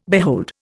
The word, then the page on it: behold: Indian